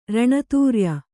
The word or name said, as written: ಋಣತ್ರಯ